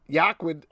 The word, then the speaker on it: Joaquin: Joaquin. – I said that right. Don't call me on it.